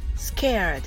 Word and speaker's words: scared: scared と seeing が韻を踏んでおり強く発音します scared は scared なのですが、どの発音はほとんど聞こえませんので、 scare と発音してください。